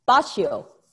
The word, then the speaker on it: Boccio: Boccio.